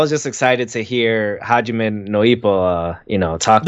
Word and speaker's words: Hajime no Ippo: When you started saying Hajime no Ippo, I say that right? – Yes.